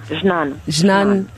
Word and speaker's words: Jnane: Jnane.